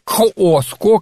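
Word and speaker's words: Cusco: Но я думаю, что есть такого, потому что огромное количество магазинов с этническими инструментами, это Куско. Вот это, собственно говоря, Куско или Коско, Коско, как оно и произносится, значит, оно представляет, это слово означает сердце на самом деле.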